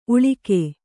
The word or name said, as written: ಊಳಿಗಿ